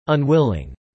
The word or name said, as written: unwilling